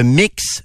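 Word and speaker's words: Mx: les élèves seront sous l'enseignement de Mix Martine trois petits points. On commence avec ça la lettre Mix Martine trois petits points. Mix se prononce mix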